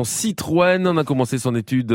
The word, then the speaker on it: Citroën: Ben oui, parce que comme on l'a dit tout à l'heure, Franck, sur Citroën, il n'y avait pas de E tréma, ce qui était difficile à prononcer. Donc effectivement, au lycée Condorcet, il a mis opportunément un tréma sur le E, puisque ça lui a donné ensuite une consonance beaucoup plus aisée.